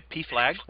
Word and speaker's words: PFLAG: And from the family's point of view, that there's also support, because there's PFLAG, did I say that right? – Yeah, PFLAG, right.